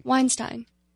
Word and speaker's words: Weinstein: Weinstein. Weinstein? Weinstein. – Well, see, I've always heard it Weinstein. – Me too. – They say on this pronouncer that it's Weinstein.